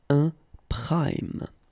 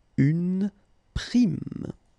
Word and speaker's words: prime: And at the masculine form, au masculin, it's actually quite interesting because you will pronounce it like un prime because it's coming from the English prime time. Un prime. For the feminine form, pour le féminin, you will pronounce it like we pronounce normally this word, une prime. Une prime.